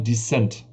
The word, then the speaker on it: Descent: Weil ich habe Redakteure es Descent ausgesprochen wird. Also nicht Decent, sondern Descent. Hm. Weil es ist ja das Gegenteil von Ascent, also aufsteigen, sondern Descent, ja, ja, Abstieg sozusagen.